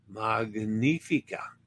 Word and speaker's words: Magnifica: Magnifica. A reminder that GN is Italian pronounced Nya. Just like the N in Spanish, as in Espanola, or in Italian lasagna, gnocchi, magnifica, magnifica.